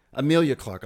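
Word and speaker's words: Emilia Clarke: Emily Clark. – Amelia. – Am I saying it wrong? What's her name? – Amelia Clark.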